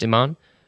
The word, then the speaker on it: Iman: That's how it's pronounced, Iman, the model.